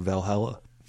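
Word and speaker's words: VA-11 HALL-A: One of the big places I saw it was in the video game soundtrack for Valhalla, or VA 11 Hall A. – That, yes, I think that's how it's meant to be pronounced.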